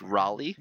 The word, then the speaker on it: Raleigh: Well, actually, you would already know, Rolly, what it is. – Okay. – Because this is why you're angry at it. – It's pronounced Raleigh, like North Carolina.